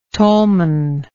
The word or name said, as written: tollman